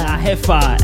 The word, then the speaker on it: La Jefa: And this song is called La Jefa. – La Jefa, L-A-J-E-F-A.